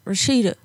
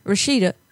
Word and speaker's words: Rashida: My name is Rashida. – It's what? – Rashida. – How do you spell it? – R-A-S-H-I-D-A.